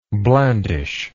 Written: blandishingly

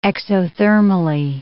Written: exothermally

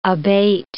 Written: abate

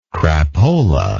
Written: crapola

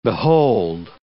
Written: behold

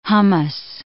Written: hummus

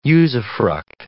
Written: usufruct

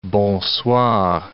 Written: bonsoir

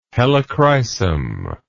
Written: helichrysum

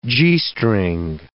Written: G-string

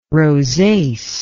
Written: rosace

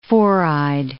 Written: four-eyed

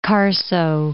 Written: Carso

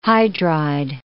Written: hydride

